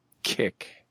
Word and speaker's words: Kik: Kick. – I'm sorry, would you spell that for me, please? – Kick. – Oh. – K-I-K.